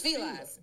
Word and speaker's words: Fila: I'm going to let you hear how it's actually pronounced. Hold on. It's Fila. – It's Fila. – See how the woman said it? How did you find that? I'm like, see, that black woman said Fila.